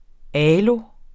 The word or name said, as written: aloe